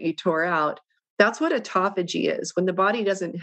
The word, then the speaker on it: autophagy: It's spelled auto, like the car, autophagy, P-H-A-G-Y. It's pronounced autophagy.